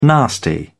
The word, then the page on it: nasty: British English